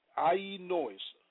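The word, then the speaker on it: aionios (αἰώνιος): The Greek word for eternal is aionios, pronounced aionios, meaning perpetual, continuing forever, also used of past time or past and future as well.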